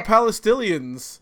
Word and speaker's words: Palestinians: Who the fuck are Palestinians? – First he said Pastillions. – No, it's hard for me to pronounce that word. – Palestinians.